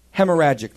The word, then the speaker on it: hemorrhagic: In 1989, the hemorrhagic disease was... Am I saying that right? Hemorrhagic, thank you.